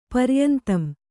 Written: ಪರಿಯಂತಂ

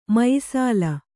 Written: ಮಾಯಿಸಲೆ

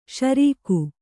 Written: ಷೆರೀಕು

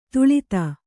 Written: ತಳೆತ

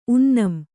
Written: ಉನ್ನಂ

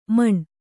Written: ಮನ

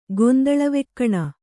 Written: ಗೊಂದಳವೆಕ್ಕಣ

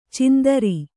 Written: ಚಾಂದ್ರ

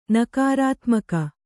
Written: ನಕಾರಾತ್ಮಕ

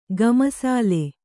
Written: ಗಮಸಾಲೆ

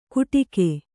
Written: ಗುಟಿಕೆ

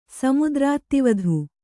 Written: ಸಮುದ್ರಾತ್ತಿವಧು

